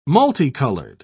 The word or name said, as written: multicolored